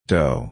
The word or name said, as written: dough